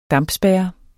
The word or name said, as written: dampspærre